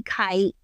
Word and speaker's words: Kight: It's Allison Lee Kight, and my last name is spelled K-I-G-H-T. So if you're looking for K-I-T-E, you'll never find me.